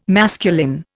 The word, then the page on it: masculine: Indian